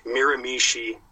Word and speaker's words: Miramichi: So we had heard through the grapevine that NWE out in Miramichi, Anthony, I'm looking at you to correct my pronunciation. – You got it. – Butchered that. I got it on that one. – Yeah, even I know that one. You nailed that one.